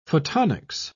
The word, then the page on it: photonics: British English